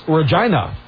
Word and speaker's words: Regina: That would be pronounced Regina.